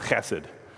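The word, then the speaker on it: chesed (חסד): Beyond be nice, and that's pronounced chesed.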